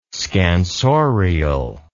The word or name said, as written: scansorial